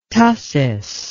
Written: tussis

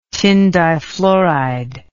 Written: tin difluoride

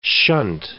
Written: shunt

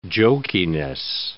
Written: jokiness

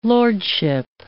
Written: lordship

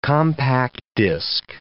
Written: compact disc

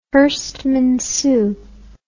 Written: Herstmonceux

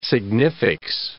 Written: significs

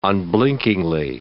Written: unblinkingly

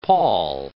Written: pawl

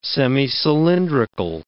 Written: semicylindrical

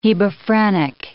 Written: hebephrenic

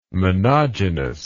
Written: monogenous

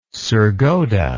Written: sargodha

